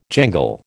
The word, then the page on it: jangle: American English